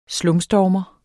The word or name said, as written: slumstormer